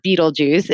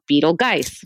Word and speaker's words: Betelgeuse: He is not Beetlejuice. It's actually spelled Beetlegeist. Beetlegeist, Beetlegeist, Beetlegeist.